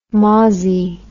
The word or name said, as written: mozzie